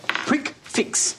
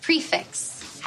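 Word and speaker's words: prix fixe: Prick fix. – Oh, hon, it's pronounced prefix.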